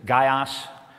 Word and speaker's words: Gaius: The first one is a man named Gaius. If you pronounce it in Greek, it's Gaios. Maybe we should say it that way.